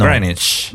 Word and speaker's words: Greenwich: Perché non si dice Greenwich con la V, non si dice la V di Greenwich. Lo sapevi? – No, no. – Greenwich.